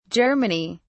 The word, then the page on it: germany: British English